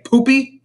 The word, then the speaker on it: Pupi: Like, the next contestant to come in is Poopy Poison, which, like, that is not me mispronouncing. That is how they say it. Poopy Poison. I don't know what that means. P-U-P-I.